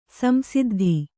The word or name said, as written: ಸಂಸಿದ್ಧ